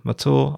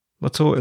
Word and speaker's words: Mathur: My name is Roy Matur. I'm a writer. Matur is spelt M-A-T-H-U-R.